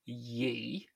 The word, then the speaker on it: ye: which in this case is ye, as which in ye old. – Yeah, which we could complain about, but it was always pronounced as a TH because it's a thorn letter.